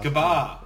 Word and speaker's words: Gabba: GABA. – No, it's pronounced GABA. It's like Canberra. And it's like Canberra and Manuka. It's just pronounced different, you know, not how it should be.